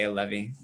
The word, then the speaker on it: Allevi: I mean, yeah, I think it goes back and forth. But we add the accent on it. And for whatever reason, we say Allevi, even though it comes from alleviate.